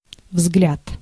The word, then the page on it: взгляд: /vzɡlʲæt/